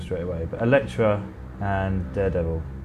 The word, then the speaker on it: Elektra: What spin-off did Jennifer Garner Electro. – No. – Oh, go on. – I mean, yeah, but you said it wrong. – Elektra. – Elektra, yes.